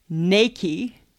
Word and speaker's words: Nike: It's actually Nike, N-A-I-K-E-Y. – No. – Yes, that's how it's pronounced.